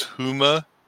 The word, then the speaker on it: Huma: Likewise, Huma, Tracy pronounces that as Huma. And I'm like, I like Huma better because it's like human, but without the N, you know?